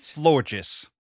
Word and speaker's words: Florges: Is it a Florges? Is that how you pronounce it? – Florges.